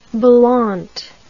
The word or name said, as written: balante